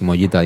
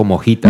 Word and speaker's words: Mollita: Me hizo gracia como pronuncia Dick Mollita, dijo Mojita. – Mojita, sí, correcto, lo escuché. mi mujer estamos escuchando y digo, ¿ese quién es? Mojita. yo, no, es Mollita, es Mollita.